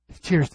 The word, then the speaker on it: Thiersch: So, in 1903, the German scholar Hermann Thiersch, you're the German guy, how do you pronounce that? T-H-E-I-R? Thiersch, there we go.